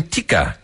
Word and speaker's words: tikka: They'll offer chicken tikka. Is that how you say that? Chicken tikka? – Of course. – T-I-K-K-A? – Absolutely. That's exactly how you say it.